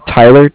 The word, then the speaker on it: Tyler: Tyler, T-Y-L-E-R. And I'm calling from Bank of Cardiff. It's spelled C-A-R-D-I-F-F as in Frank.